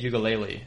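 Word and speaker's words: Uiagalelei: So you said DJU, it's DJ Ugalele.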